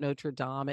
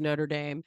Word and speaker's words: Notre Dame: And I said, "Well, the the commonly anglicized pronunciation of it is not Notre Dame; it is Notre Dame."